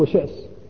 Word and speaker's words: شأس: بالنسبة للعلم العربي المعروف في الجاهلية شأس، وأما ابن شأس المالكي المتأخر فإن المصريين ينطقونه بتسهيل الهمزة لثقل النطق بها، ولكن العلم العربي المعروف هو شأس بالهمزة، مثل عرار بن عمرو بن شأس الذي هو صحابي، وأبوه أيضا عمرو بن شأس صحابي، فهو ابن شأس بالهمزة.